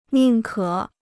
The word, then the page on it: 宁可: nìng kě